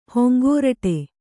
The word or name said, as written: ಹೊಂಗೋರಟೆ